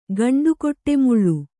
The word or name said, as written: ಗಂಡುಕೊಟ್ಟೆಮುಳ್ಳು